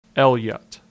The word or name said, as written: Elliott